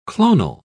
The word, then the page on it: clonal: British English